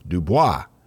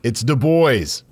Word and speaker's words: Du Bois: Having previously lived in Maine and around a lot of French people, I pronounced the name Du Bois. And someone laughed at me and said, we're not that fancy here. It's Du Bois.